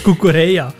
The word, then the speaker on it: Cucurella: Der Cucurella, habe ich es richtig ausgesprochen? Nick Schindler. – Richtig, der Marc Cucurella.